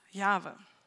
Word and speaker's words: Jahwe: Und deswegen ähm, haben wir diese Buchstaben J, H, W, H, die zwar hier auf der Bühne stehen, die auch auf den, auf den Flyern oder digitalen Medien waren, aber aussprechen wollen wir es Jahwe.